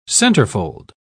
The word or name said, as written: centerfold